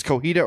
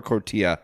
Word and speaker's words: cotija: I always say it wrong. It's cojita or cotija cheese.